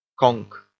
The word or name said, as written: conk